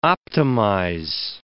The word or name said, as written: optimize